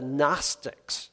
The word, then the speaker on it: Gnostics: Gnostics. That's a word that has a G on the end. It's a silent G, but it's taken from the Greek word gnosis, which means wisdom.